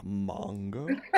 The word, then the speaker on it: manga: Originally, this movie was from a manga. I said that correctly? – Creature Walks a manga. – Yeah, manga.